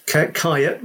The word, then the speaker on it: Kyiet: Luckily, I've got a unique surname, so it's Kyiet, which is spelled K-Y-I-E-T.